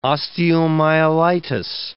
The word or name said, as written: osteomyelitis